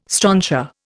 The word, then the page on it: strontia: Indian